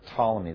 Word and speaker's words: Ptolemy: The Ptolemies, PT, etc., Ptolemy, the P is silent, but the Ptolemies basically got Egypt.